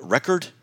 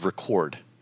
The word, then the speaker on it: record: When a word is both a noun and a verb, the accent is on different parts of the word. So think about the word record and the word record. If it's a noun, record, right? This is a record player. If I'm doing what we're doing now with the show, we are recording it, right? So the accent is on the different syllable.